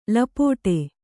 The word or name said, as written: ಲಪಾಟಿ